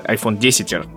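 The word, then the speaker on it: iPhone X: iPhone 10 правильно же говорить, черт. Да, правильно говорить.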